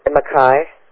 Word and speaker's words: maqui: No, M-A-Q-U-I. – Q-U-I, okay. – Yeah. – And how do you pronounce that? – Mackay.